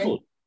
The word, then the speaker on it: Trumble: Trumble.